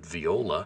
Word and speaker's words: viola: I said, viola? It rhymes with granola.